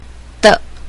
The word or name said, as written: de